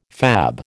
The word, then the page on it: fab: American English